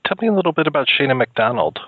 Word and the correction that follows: Sheona McDonald: Sheona McDonald. Uh, yeah, I said it wrong in the beginning too until she corrected me.